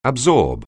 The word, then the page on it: absorb: anglais